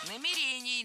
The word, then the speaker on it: намерений: Сказали, что у нас нет никаких намерений предыдущего руководства. – Намерений, намерения – это неверно. Правильно, намерение, намерений. Ударение в этом слове ставим на второй слог и не меняем это ударение при изменении слова.